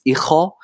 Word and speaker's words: ichó: Eigentlich, äh, wenn wir es nicht eingedeutscht aussprechen, wird es Icho ausgesprochen Aha. und Icho ist das griechische Wort für Echo.